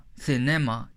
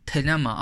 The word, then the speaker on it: Thelema: Thelema, Thelema.